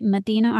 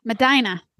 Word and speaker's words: Medina: And he is out of, and I'm going to say it wrong, but I believe this is right, Medina, Ohio. Medina. I knew I was saying it wrong.